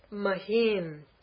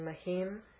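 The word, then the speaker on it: mahīm: For the next one, mahim. Do you see a long e? You see it in the transliteration, mahim. Do you see it in the word mahim up there, that long e? It goes the other way, right?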